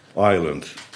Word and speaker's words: Island: Hoewel ze het net zo uitspreken. Island. Maar er zit wel een S tussen.